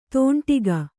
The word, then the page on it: ತೊಂಡಿಗ: Kannada